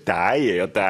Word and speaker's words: dig: D-I-G. – 네, 이게 너예요. 네. D잖아요. – D로 읽어야 될 텐데. – 근데 die예요. die. 그래서 이걸 읽으면서 그 이제 붕괴가 오는 거죠.